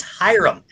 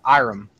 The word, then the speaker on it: Hiram: You pronounce the H in either case. Around here, it's just Iram. Like, Iram. There's no H.